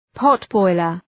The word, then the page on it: potboiler: ‘pɒt,bɔılər